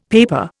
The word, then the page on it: paper: Indian